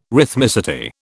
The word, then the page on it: rhythmicity: British English